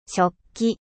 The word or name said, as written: 食器